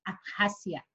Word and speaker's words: Abjasia: Abjasia.